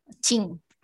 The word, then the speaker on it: Qing: ¿Así se pronuncia? ¿Qing? Qing. perfecto.